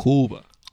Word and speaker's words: Cuba: Cuba. – Cuba. – The Q. Barely pronounced the Q. O-O-B-A.